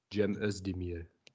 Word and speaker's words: Cem Özdemir: darfst es einmal richtig sagen. Cem, ne? – Cem Özdemir.